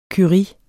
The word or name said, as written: curie